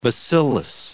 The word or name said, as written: bacillus